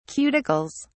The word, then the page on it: cuticles: British English